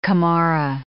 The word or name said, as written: camorra